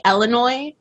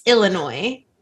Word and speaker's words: Illinois: It's, it's, I used to say Illinois, but it's Illinois.